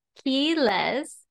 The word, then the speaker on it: Quiles: And my last name, Quiles, is spelled with a Q, but in Spanish, the Q is pronounced with a key instead of a Q or Q. So my last name is two syllables, Quiles, and the way I tell people to pronounce it is like the word key, like a key that you use to open a door, and less, like not more, less.